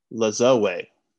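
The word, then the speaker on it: Lajoie: And I looked it up because I've always been curious how to pronounce his name, but it's Nap Lazoe. – Yes. – Because it's French. It is French.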